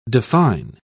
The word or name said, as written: define